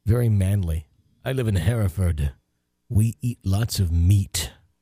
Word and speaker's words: Hereford: Because I say it wrong, and it looks like Hereford, but it's... – Hereford. – Hereford. Hereford, England.